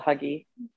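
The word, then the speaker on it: hygge: And it's H-Y-G-G-E, right? And it's, how's it supposed to be pronounced? – Huga. – And I just call it Huggy.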